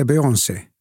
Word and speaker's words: Beyoncé: Jag tror att det korrekta är Beyoncé. – Rätt. – Ja. Ja. När jag upptäckte när jag till exempel Beyoncé sjöng Halo, så är det en av de tre, fyra Adeles Rolling Deep, också fantastiskt bra.